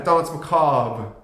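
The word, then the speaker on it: Danse Macabre: Yeah, for Dance Macabre. – Good yep. pronunciation. – Thank you. I was waiting for you to acknowledge that. – The dramatic pause, the slight roll on the R.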